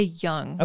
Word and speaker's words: Junge: I would say Jung. – Okay, that's what I think too, but it's spelled mm-hmm. J-U-N-G-E.